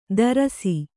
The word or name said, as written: ತಾರಸಿ